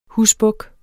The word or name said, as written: husbuk